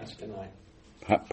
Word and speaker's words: Clatskanie: Clatskanie.